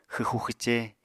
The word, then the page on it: xwxu-xwce: Apatani